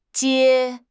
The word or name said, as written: 皆